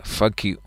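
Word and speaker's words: FKÜ: Se llaman, bajo un acrónimo, F.K.U, con diéresis. Es decir, fuck you. Más o menos se pronunciaría así, ¿no?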